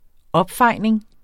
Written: opfejning

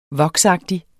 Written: voksagtig

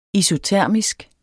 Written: isotermisk

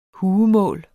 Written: huemål

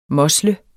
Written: mosle

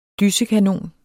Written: dysekanon

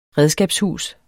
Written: redskabshus

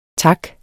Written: taks

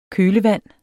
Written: kølevand

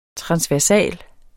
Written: transversal

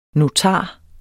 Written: notar

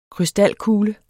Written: krystalkugle

